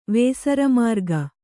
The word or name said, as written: ವೇಸರಮಾರ್ಗ